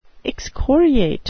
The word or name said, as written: excoriate